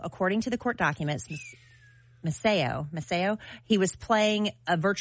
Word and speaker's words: Maceo: How do you say M-A-C-E-O? – Maceo? – Maceo.